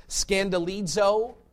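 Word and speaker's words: skandalizō: This word stumble in the Greek is pronounced scandalizo. You hear our English word scandal in that.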